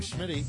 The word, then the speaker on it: Schmitty: Hi, I'm Schmitty.